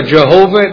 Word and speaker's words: Jehovah: Pronounce it either Yahweh or Jehovah.